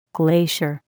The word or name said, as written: glacier